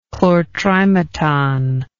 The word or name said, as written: chlortrimeton